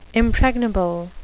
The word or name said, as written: impregnable